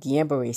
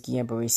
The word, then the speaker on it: Guillain-Barré: But I know how to at least pronounce it in the French way. So it's called Guillain-Barré syndrome.